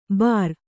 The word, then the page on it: ಬಾರ್: kannada